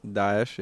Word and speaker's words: Daesh: Did I say it right? – Daesh, yeah.